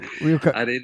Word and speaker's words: Ryuho: Ryuho. – It's not difficult for you to pronounce Ryuho.